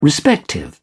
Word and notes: respective: British English

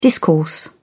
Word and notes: discourse: British English

